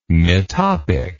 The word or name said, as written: metopic